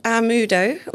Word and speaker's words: Amaudo: It's called Amudo, which is spelled, I'll spell it, A-M-A-U-D-O.